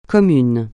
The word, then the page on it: commun: francais